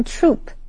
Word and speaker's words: troupe: Okay, 继续我们看 troop 这个名词 troop 虽然和 troop, T-R-O-O-P 是同样的发音,但是意思不一样。 T-R-O-O-P 是指军团,而今天我们看的这个 T-R-O-U-P-E 是指戏剧,或者是表演队,或者是戏班子。例如, Chelsea works with different dance troops and performs in various states.